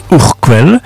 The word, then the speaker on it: Urquell: On écrit Urquell, mais on prononce Urquell, qui est brassée à Pilsen, dont le nom a donné le mot Pils.